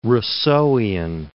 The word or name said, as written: Rousseauian